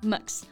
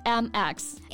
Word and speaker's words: Mx: Most people pronounce it as Max with a sort of schwa sound, 就中间加了一个 schwa 的音啊。 But actually, a lot of people just spell it out, like MX.